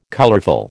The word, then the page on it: colourful: American English